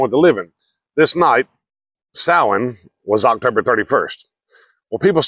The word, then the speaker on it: Samhain: Now, it's spelled S-A-M-H-A-I-N, but it says it's pronounced Samhain. S-O-W-E-N.